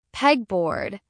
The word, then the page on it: pegboard: American English